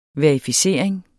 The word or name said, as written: verificering